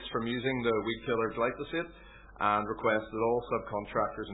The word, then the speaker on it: glyphosate: 18 is the use of weed killer glyphosate.